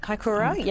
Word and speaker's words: Kaikoura: Kaikoura, yes.